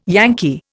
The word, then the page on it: Yankee: Indian